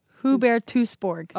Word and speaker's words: Hubertusburg: Hubertusburg.